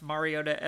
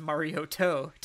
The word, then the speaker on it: Mariota: Goodell mispronounced Mariota as Mariotto.